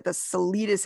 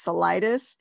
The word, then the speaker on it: Salidas: It's actually Greek. Okay. And so that's why it's not the Salidas, it's Salidas.